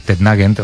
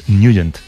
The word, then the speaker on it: Nugent: Hombre, siempre lo hemos llamado en el barrio Tednagent o Tednagent. – Claro, ¿verdad? Yo era de Tednagent. – Pero la pronunciación correcta es Nugent.